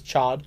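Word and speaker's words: Ch'od: Who's pronounced as Chod, but it's Chod.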